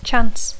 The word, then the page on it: chance: English